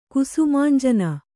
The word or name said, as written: ಕುಸುಮಾಂಜನ